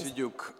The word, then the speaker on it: Siduk: Noi in realtà li avevamo invitati tutti e due, sia Sergio sia It's Boris Siduk, the pronunciation of Boris. – Siduk. – I always say Siduk, I'm sorry.